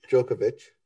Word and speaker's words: Djokovic: You always do this. Djokovic. – Oh my gosh. I always pronounce the D. – Yep. Djokovic.